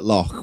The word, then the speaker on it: loch: Well, it just so happens that loch, with exactly the same pronunciation as the Scottish pronunciation, is the Polish word for dungeon.